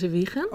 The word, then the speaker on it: Wijgen: Ik ga je meteen wel vertellen dat wijgen hier uitgesproken wordt als wiegen. Oh, wiegen. – Wiegen, zoals je een babytje wiegt.